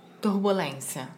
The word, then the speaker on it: turbulência: Como é que fala isso? Turbulância. – Turbulência. – Turbulência.